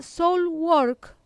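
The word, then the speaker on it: Soilwork: Telonearon a Soulwork, lo digo bien, me lo está diciendo perfecto.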